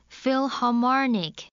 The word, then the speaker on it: Philharmonic: How do you pronounce the word? – Philharmonic. – Philharmonic.